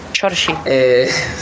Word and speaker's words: Saoirse: si es que se pronuncia así, Saoirse. – Saoirse. – Saoirse, es complicado.